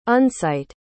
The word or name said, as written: unsight